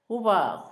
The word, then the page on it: gobago: Zapoteco; Asunción Ocotlán